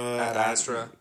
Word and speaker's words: Ad Astra: Speaking of movies that we don't really want to see, there was a trailer for Ad Astra, which Is that even how you pronounce it? Ad Astra? – Ad Astra.